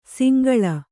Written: ಸಂಕುಲ

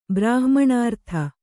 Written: ಬ್ರಾಹ್ಮಣಾರ್ಥ

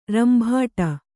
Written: ರಂಬಾಟ

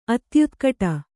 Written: ಅತ್ಯುತ್ಕಟ

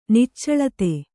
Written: ನಿಚ್ಚಳತೆ

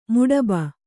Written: ಮುಡುಬು

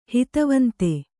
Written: ಹಿತವಂತೆ

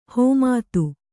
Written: ಹಿಮ್ಮತು

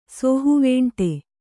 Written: ಸೋಹುವೇಂಟೆ

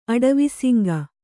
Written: ಅಡವಿಸಿಂಗ

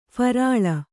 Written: ಫರ್ಲೋ

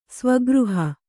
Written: ಸ್ವಗೃಹ

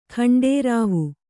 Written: ಘಂಟಾರವೆ

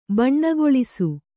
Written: ಬಣ್ಣಗೊಳಿಸು

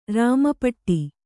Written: ರಾಮಪಟ್ಟಿ

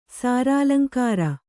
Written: ಸಾರಾಲಂಕಾರ

